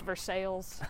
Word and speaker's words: Versailles: Like in Kentucky, our most notable one, or at least the one that comes to mind for me, is a town called Versailles. – Mm-hmm. – But it's pronounced Versailles. – Of course. – By us.